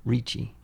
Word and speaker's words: Ricci: How do you say her last name? Christina? – Ricci. – Ricci. – R-I-C-C-I.